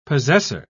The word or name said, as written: possessor